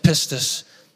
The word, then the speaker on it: pistis: The Greek word for faith, pistis, you got to be careful how you say it, but it's called pistis. And this Greek word pistis, it means both faith and faithfulness.